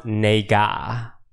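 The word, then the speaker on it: nega (네가): It's a Korean word, and it's pronounced nega.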